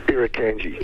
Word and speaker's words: Irukandji: Irukandji. – Irukandji.